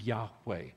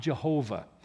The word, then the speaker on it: Yahweh: It is not Jehovah. They don't have a J or a V in the Hebrew alphabet. It would have been physically impossible for them to have pronounced His name, Jehovah.